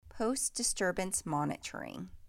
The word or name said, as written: Post-disturbance monitoring